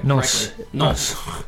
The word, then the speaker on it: Nantes: Nantes.